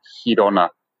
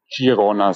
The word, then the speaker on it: Girona: Die Aussprache eines Stadtnamens und zwar Girona, die Stadt in Spanien. Das heißt Girona. Als spanischer Native Speaker stellt es mir jedes Mal die Nackenhaare auf, wenn ihr Girona sagt, wie der Giro in Italien.